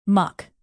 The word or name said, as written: muck